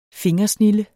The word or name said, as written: fingersnilde